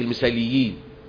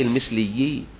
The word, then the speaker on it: المثليين: المثلي، حتى في بعض الناس بينطقوها غلط يقول لك المثاليين، المثاليين، أقول له لا المثليين، شفت اجتماع المثاليين؟ أقول لا ده المثليين، المثاليين يعني الناس الإيه؟